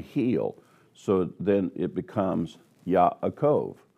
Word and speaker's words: Yaakov: The name Yaakov, that's the way it's pronounced in Hebrew, is actually a combination of a Hebrew letter with the word Ekev.